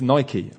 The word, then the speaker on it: Nike: And it is Nike, apparently, as well. I looked it up. So it rhymes with spiky and not bike.